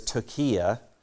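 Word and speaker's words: tokia (πτωχεία): But this is a different word for poverty, and the Greek word is tokia. Give you Greek in small doses, the P is silent.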